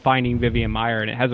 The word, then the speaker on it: Vivian Maier: And then all of a sudden, I mean, great example, Vivian Maher, the photographer. – Yeah, Vivian Maier is great. – Did I say that right? Okay.